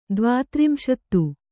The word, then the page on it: ದ್ವಾತ್ರಿಂಶತಿ: Kannada